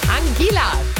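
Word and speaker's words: Anguilla: Angela. Habe ich es richtig gesagt, Simon? – Ja, würde ich sagen, weil es tatsächlich zu Großbritannien mit dazu gehört.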